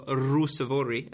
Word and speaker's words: Ruusuvuori: Emil Ruusuvuori, or Emil Ruusuvuori, as I learned how to properly pronounce.